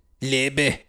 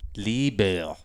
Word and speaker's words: Liebe: Liebe. – Liebe. – Liebe. – Liebe. – Liebe. – I feel like you're putting way too much emphasis on the end there.